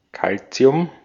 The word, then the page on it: Calcium: /ˈkalʦi̯ʊm/